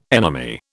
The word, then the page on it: enemy: British English